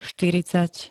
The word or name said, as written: štyridsať